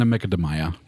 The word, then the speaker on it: Macadamia: Das wird ja eigentlich Macadamia ausgesprochen. – Ja, auf eine Macadamia.